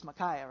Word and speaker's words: Micaiah: And so I went back and looked, and it turns out it's Micaiah. And I know it's the same phonetically as Isaiah. Some people say Micaiah. But for sanity's sake this morning, what I've been practicing all week is Micaiah, right?